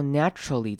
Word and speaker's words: naturally: 好，看到 naturally 这个字比较特别，这边念法是 na 前面开头短母音 a tr，然后最所以我们不会念成 tur 的声音，而是比较像是 tr natural，然后后面再接 ly naturally。